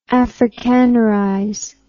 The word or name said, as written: afrikanerize